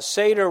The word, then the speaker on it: Seder: So I thought long and hard about it, and I realized one day that Seder rhymes with one of our past presidential candidates, Ralph Nader. And then I thought about it some more, and I realized that it also rhymes with Darth Vader. So when you leave here this morning, you can kind of repeat to yourself, Nader, Vader, Passover Seder, like that.